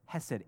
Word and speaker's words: hesed: Hesed, H-E-S-E-D, hesed.